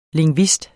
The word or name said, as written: LINGVISTER